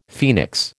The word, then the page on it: phoenix: American English